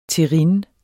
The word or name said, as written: terrine